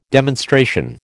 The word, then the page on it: demonstration: American English